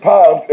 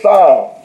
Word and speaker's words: psalms: But it ain't palms, it's psalms. Let me say it again. The P is silent.